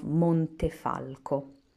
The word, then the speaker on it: Montefalco: Sagrantino is the name of a grape and the red wine made from it, primarily around the town of Montefalco.